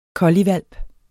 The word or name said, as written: colliehvalp